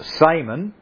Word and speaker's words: semen: S-E-M-E-N, pronounced in classical Latin with a long first E as semen, and in ecclesiastical Latin with a short first E as semen.